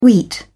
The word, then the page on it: wheat: British English